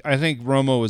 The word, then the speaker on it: Romo: I think Romo is a very good quarterback. – You got to pronounce it. It's Romo. I'm Team Romo. – Okay. – Not Team anything else.